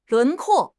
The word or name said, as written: 轮廓